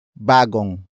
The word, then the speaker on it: bagong: But first, we have bagong.